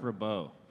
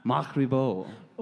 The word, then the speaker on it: Ribot: Oh, also ask them about seeing/playing with Mark Ribbit. Ribbit? – Mark right. Ribot. – Ribot. I'm too white for this. Ribbit? – Is that French? – Probably. The T is silent. – Mark Ribot.